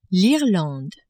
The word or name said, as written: Irlande